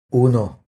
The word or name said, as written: Uno